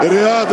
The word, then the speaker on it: Riyad: Och på arabiskspråkiga Bein Sport uttalas huvudpersonen bäst. Riyad, Riyad, Riyad, Riyad, Riyad!